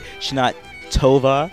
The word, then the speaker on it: Shana Tova: Can I pronounce that? Shana Tova. – Tova. – Shana Tova. – That was kind of, you felt kind of aggravated. Shana Tova. All right, nailed it.